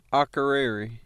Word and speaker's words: Akureyri: We're in Akureyri. I hope I'm pronouncing that right. – Akureyri.